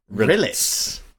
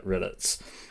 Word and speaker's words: rillettes: Rillets is the thing that I was thinking about. I've been Googling non-stop trying to figure out what I was on about. – Rillets. – Yeah, Rillets. – How do you spell it? – R-I-L-L-E-T-T-E-S.